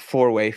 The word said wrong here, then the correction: foray: foray is that how you say it? Foray yes, into yes, new gaming.